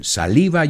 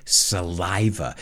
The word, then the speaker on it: saliva: Just be careful with the pronunciation. Saliva, you say, we say saliva.